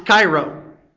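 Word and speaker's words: kairo (χαίρω): It's spelled C-H-R-I-O. It's pronounced kairo.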